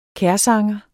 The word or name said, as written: kærsanger